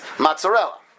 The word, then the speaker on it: mozzarella: Okay, now, the way we pronounce it in American English, I'm assuming in English is also, is mozzarella.